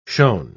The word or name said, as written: shown